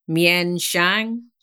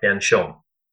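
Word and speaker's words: Mien Shiang: she just looks at me So there's some subtleties in the pronunciation. It's something like Menchon.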